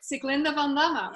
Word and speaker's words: Sieglinde Van Damme: Sieglinde van Damme.